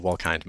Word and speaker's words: Wolkind: It's actually a long I, so Wolkind most of the time. But people frequently get it wrong, so I respond to both.